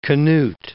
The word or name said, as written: Canute